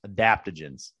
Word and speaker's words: adaptogens: Adaptogens. Damn, we've been saying it wrong. Adaptogens. To start your day. – No, we haven't said it. It's adaptogens.